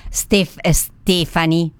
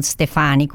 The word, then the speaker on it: Stefani: E tra l'altro ringrazio anche chi mi ha corretto sulla pronuncia del governatore del Veneto, del presidente della regione Veneto, Stefani, non Stefani come ho detto io.